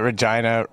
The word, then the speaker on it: Regina: The fact that Regina rhymes with vagina is something that's like...